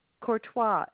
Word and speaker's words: Courtois: Patricia Courtois. It's spelled C-O-U-R-T-O-I-S.